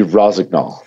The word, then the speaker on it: Rossignol: Yeah, it would be Rossignol.